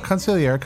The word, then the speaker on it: consigliere: Hit it, Kev! – Consigliere. – Consigliere. – Consigliere. – No, not consigliere, consigliere. – Consigliere. – In different parts of Italy, you would pronounce consigliere. – The G. – Yeah, play it again. – One more time. – Consigliere.